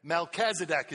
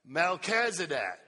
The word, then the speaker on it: Melchizedek: Melchizedek is how you say it. Let's practice that. Melchizedek.